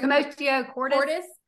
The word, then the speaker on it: commotio cordis: Commotio cordis.